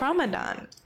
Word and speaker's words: Ramadan: May 15th will be the first night of Ramadan night.